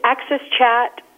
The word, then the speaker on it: AXS Chat: It is Access Chat, and it is, but it's spelled A as in Apple, X as in X-ray, S as in Sam, then chat, AXS Chat.